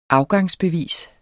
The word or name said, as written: afgangsbevis